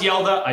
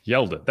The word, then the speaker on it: Hjalte: It's pronounced Yelda. I've double checked it 1,000 times. In fact, here, you want, I got my handy dandy Browns pronunciation guide here somewhere. – I could have tried 12 different pronunciations. I would never have gotten to Yelda. For anyone wondering, it is spelled H-J-A-L-T-E. Yelda.